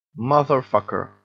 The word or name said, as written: motherfucker